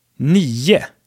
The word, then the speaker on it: nio: All right, so these were the numbers 1 through 10, and I just wanted to point out that we often pronounce nio and tio, 9 and 10, as nie and tie so that ending O turns into some kind of schwa, like unstressed thing.